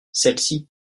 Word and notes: celles-ci: french

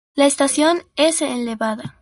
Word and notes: elevada: espanhol

